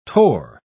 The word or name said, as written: tor